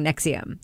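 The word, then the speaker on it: NXIVM: which I now know how to pronounce thanks to the podcast Uncover Escaping Nexium.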